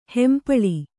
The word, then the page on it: ಹೆಂಪಳಿ: hempaḷi